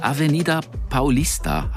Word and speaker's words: Avenida Paulista: Die Avenida Paulista, habe ich richtig ausgesprochen, oder? – Mhm.